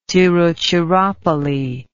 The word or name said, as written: tiruchirapalli